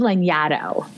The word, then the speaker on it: Lanyadoo: My name is Lanyadoo. – Lanyadoo. – Yeah, it's nobody can pronounce it. It's not phonetic. It's not English. It's fine with me. But if you're going to butcher it, think of Xanadu.